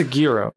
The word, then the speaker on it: gyro: Like that's a gyro, right? That's like G Y R O, like a hero.